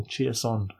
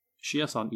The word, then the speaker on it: Chiasson: Are you familiar with the player known as Alex Chiasson, or Chiasson, however you want to pronounce it? – Chiasson, yes. Him